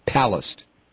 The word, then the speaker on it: Palast: Accent is on the first syllable. Palast.